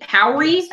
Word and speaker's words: Howery: Lil Rel eight Howery, I believe is how his now his last name is pronounced.